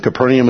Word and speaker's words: Capernaum: Capernaum is kind of a common pronunciation in English carried over.